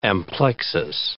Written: amplexus

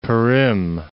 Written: Perim

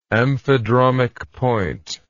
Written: amphidromic point

